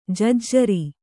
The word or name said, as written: ಜಜ್ಜರ